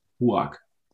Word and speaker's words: Ruark: R-U-A-R-K. – Huark.